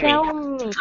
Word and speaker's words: Xiaomi: Este, lo que se me hace interesante es que este hombre se va a ir a, creo que se pronuncia Xiaomi, ¿eso? Xiaomi. Xiaomi. – Xiaomi.